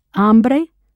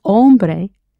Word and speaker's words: hambre: ¿Tienes hambre? And one more thing. Be very careful to pronounce hambre correctly. Because if you say hombre, it means you have a man.